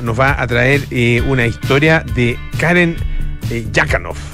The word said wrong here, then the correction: Khachanov: Kachanov, eso, es Kachanov. Eh, eh, es que es, como Hachanov. – Se escribe con J, veo. – Con, o con KH lo escribe.